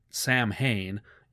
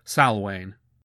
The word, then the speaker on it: Samhain: And yes, yes, the first thing pedants always like to point out is that in Gaelic, you wouldn't pronounce it Samhain, it's more like Salwain.